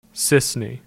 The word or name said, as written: Cisne